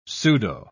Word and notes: pseudo-: British English

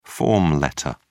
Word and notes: form letter: British English

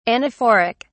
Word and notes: anaphoric: British English

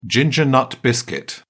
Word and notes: ginger snap: British English

